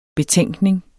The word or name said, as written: betænkning